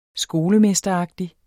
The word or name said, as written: skolemesteragtig